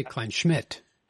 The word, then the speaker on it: Kleinschmidt: I always say Kleinschmidt with emphasis on the second syllable.